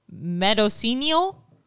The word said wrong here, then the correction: Mendocino: Wait, how do you say that name? Medicinal? – Medicinal? That's not it. Which one are you on? Okay. Mendocino. Mendocino County, California.